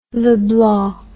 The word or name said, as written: leblanc